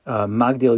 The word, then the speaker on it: Magdil: As an aside, in Harachaman on Shabbos, there's a change in how we pronounce one of the words. During the week, we say Magdil Yeshuos Malko, and on Shabbos we say Migdol Yeshuos Malko.